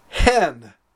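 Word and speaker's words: Hen (ἕν): Hen. It's pronounced like the chicken. Hen. The difference is that the number 1 has a rough breathing and then is therefore pronounced hen.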